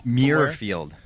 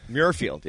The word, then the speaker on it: Muirfield: Muirfield. – Muirfield. Yeah. Muirfield. Boom. – I was watching the news last night with David Muir. and that was helping me pronounce Muirfield.